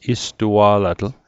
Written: istua'latl

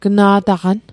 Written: gna'taqan

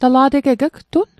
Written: ugtun

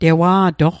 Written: tewa'toq

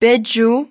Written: peju

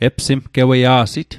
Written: epsimgeweia'sit

